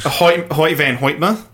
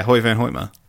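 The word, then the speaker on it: Hoyte van Hoytema: That's not his name. – For try again. – For Dunkirk. That is how you say his name. – Is it? – Yeah, Hoyt Van Hoytmer. – Hoyt Van Hoytmer. – Yeah. H-O-Y-T-E space V-A-N space H-O-Y-T-E-M-A. – His parents are dicks.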